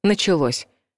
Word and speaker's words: началось: В этом глаголе ударение падает на последний слог, хотя в неопределенной форме глагола ударение стоит в другом месте — «начаться». Лингвисты приводят такую закономерность. Достаточно часто в возвратных глаголах ударение в прошедшем времени переходит на окончание. Например, «начаться» — «началось», как и в предыдущем примере с «приняться».